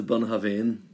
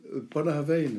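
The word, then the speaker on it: Bunnahabhain: That other bottle I bought you, can you pronounce that? I haven't a clue what it is. – Yes, yeah, the Bunhavain. – Bunhavain, is that your that's right, yeah.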